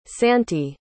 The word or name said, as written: santy